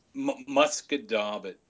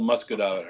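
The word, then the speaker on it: Musquodoboit: Musket-dabit. If anyone even wants to try and pronounce it the way it's spelled, forget it. But it's called Musket-dabit Harbor.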